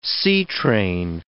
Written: seatrain

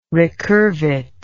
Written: recurvate

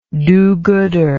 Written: do gooder

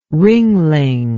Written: Ringling

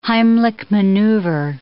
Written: Heimlich maneuver

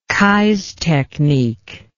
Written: Keyes technique

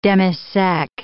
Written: demi-sec

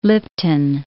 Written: Lipton

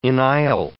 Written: enisle

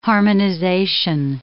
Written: harmonization

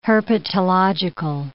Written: herpetological

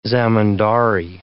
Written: zemindary

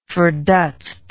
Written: ferdutzt

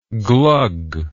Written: glug

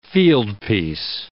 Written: fieldpiece

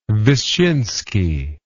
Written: Wyszynski

